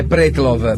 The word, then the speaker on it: Pretlow: Ma sarà sulla E o sulla O l'accento? Pretlow o Pretlow? – Ma no, è Pretlow. Pretlow, diciamo, è più russo, Pretlow, secondo me, e non avrebbe la E finale.